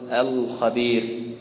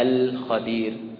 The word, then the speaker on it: الخبير: كذلك يراعي ترقيق هذه اللام، يراعي ترقيق هذه اللام، فعندما ينطق بكلمة القمر لا يفخم اللام، لا يقول القمر لأجل أن القاف مفخمة، ولا يقول الخبير لأن الخاء مفخمة، طيب لكن يقرأها هكذا الخبير القمر كما يقرأ كلمة الجبال، واضح؟